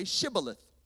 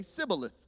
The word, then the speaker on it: Shibboleth: If the man said, "No, I'm not," they would tell him to say Shibboleth. And if he was from Ephraim, he would say Sibboleth, because people from Ephraim could not pronounce the word correctly.